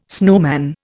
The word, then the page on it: snowman: Indian